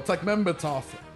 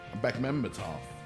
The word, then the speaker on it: Bekmambetov: Well, Bekmambetov, I said his name wrong. Bekmambetov was talking to some people.